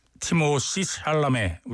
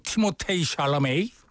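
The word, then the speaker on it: Timothée Chalamet: 김혜원 씨가 청해 주신 곡, 원카 사운드트랙에서 티모시 샬라메, 우리 이렇게 발음하죠? 발음은 뭐 원래는 뭐 티모테이 샬라메이 뭐 이렇게 발음한다고 그러는데, 예.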